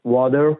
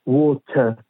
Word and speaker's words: water: مثل کلمه water که در انگلیسی آمریکایی وادر خونده میشه با آر غلیز در انگلیسی بریتانیایی و استرالیایی ووت خونده میشه یعنی آرش تلفظ نمیشه